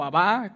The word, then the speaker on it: Abba: Por eso cuando Jesús llega y comienza a enseñar y a decir, Padre, Padre, Padre, Incluso utiliza la palabra Abba o Abba, como realmente se debe pronunciar.